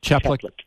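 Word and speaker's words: Chaplik: This sponsor, Representative Joseph Chiplick, is that how it's pronounced? – Chiplick,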